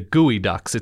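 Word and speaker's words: geoduck: So I think there is a mascot that is the Gooey Ducks. It's spelled Geoducks.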